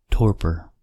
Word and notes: torpor: American English

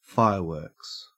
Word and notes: fireworks: Australian English

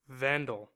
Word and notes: vandal: American English

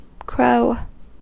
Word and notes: crow: American English

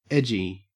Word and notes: edgy: Australian English

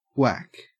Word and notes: whack: Australian English

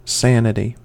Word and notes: sanity: American English

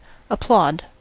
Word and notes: applaud: American English

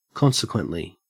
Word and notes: consequently: Australian English

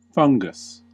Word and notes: fungus: American English

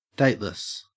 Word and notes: dateless: Australian English

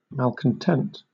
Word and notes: malcontent: British English

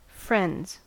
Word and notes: friends: American English

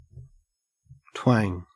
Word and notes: twang: Australian English

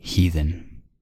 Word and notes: heathen: American English

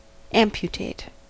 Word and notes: amputate: American English

